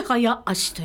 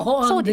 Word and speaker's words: 早足: うん、で早足と言うんです、はい、速歩とも速歩ですね。そうです、速歩ですけども、うんうんうん、まあ、当時の新聞に行くと、ええ、早足と読むべしと書かれてますので、まあ、私は早足って読んでます。